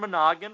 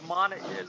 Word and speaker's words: Monaghan: First of all, for Choice TV Villain, two DC nominees here, Cameron Monaghan from Gotham and Odette Annable from Supergirl. – I'm pretty sure that's not how you pronounce it, but we'll go with it. – Oh, right. It's Monaghan.